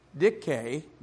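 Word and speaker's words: Dike: Dick K, D-I-K-E, long E, is the name of one of the goddesses.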